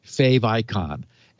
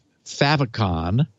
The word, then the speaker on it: favicon: And pretty much every site you go to will have its own fave icon. And yes, I've heard it pronounced favicon.